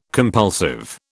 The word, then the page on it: compulsive: British English